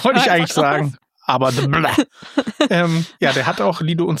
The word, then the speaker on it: DeBlois: Ich glaube, der heißt DeBlois. – DeBlois. – Der ist aus Quebec, Kanada. Da spricht man Französisch.